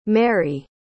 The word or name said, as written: merrie